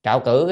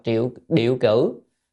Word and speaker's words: trạo cử: Trạo cử có nghĩa là cái chữ này còn gọi đọc là triệu điệu cử. Nhưng mà thường hay đọc trạo cử hơn.